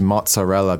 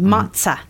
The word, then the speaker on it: mozzarella: And then there's the third one, which is mozzarella. Be careful of the pronunciation of this one. It's not mozzarella. – No, it's with a T sound, mozza.